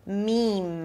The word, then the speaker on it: meme: Meme, you start with an M sound, a high E as in see, and then you close it with another M. Meme, make sure you close your lips for the M, otherwise it's gonna sound like mean or something.